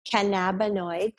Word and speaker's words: cannabinoid: Cannabinoid. Think cannabis.